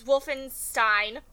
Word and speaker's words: Wolfenstein: The most recent game, correct me if I'm wrong, was Wolfenstein. – You said it right. Yes, good job.